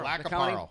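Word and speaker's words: Lac qui Parle: Got official notice from, how do you say that, Lac-a-Parle? Is that how you say that? – Lac-a-Parle. – Yeah. – Lac-a-Parle County.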